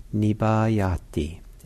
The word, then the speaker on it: nibbayati: So nibbayati, N-I-B-B, long A, Y-A-T-I.